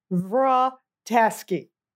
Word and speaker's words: Vratasky: Sometimes it sounds like Rita, Rhea, Potasky, Vrotasky. It's Vrotasky.